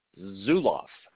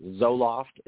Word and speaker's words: Zulauf: Austin Zulauf, and people murder that last name regularly, so I appreciate you asking me to pronounce it correctly. I've been called everything. Zulauf is actually pretty common, and so I had to make the jokes.